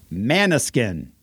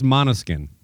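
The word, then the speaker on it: Måneskin: Manaskin. Have you heard of this band? – Yeah, are you saying? I think it's Manaskin. – Manaskin? – Yeah. – Manskin? Has an umlaut.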